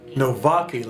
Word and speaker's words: Nowacki: Can you pronounce her last name for us? – Nowacki, like a V.